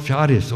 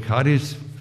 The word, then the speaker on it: Charis: Das Wort Gnade ist das Wort Charis oder Charis, verschieden ausgesprochen.